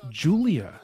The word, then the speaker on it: Julija: I think it's Julija, but it's pronounced Julia. – Okay, because that's what my husband kept saying, is that it's pronounced Julia, and I was like, no. – It is pronounced Julia, because my wife, who's from Eastern Europe, Russia, whatever, she always makes fun of me, and she's like, it's not Julija, it's Julia.